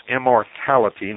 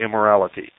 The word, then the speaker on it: immortality: It's immortality, not immorality. Immortality.